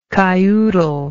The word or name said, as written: kyoodle